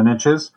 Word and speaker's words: niche: I know it's pronounced niches, but you know, it doesn't rhyme. So the riches are in the niches.